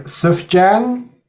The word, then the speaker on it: Sufjan: Hopefully I'm saying his name right. Sufjan Stevens? – I think that's correct.